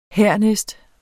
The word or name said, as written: hernæst